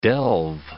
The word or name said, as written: delve